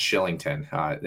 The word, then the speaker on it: Kylington: It's a Irish or Scottish name, and it's spelt Kylington, but it's pronounced Shillington for whatever reason.